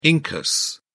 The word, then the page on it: incus: British English